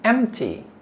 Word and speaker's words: empty: Full, full, and empty, empty. Notice we don't say empty, no P. P is silent.